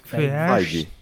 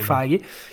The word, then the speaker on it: Feige: però secondo me semplicemente diciamo Kevin Feige, non so pronunciarlo, Feige. Kevin Feige.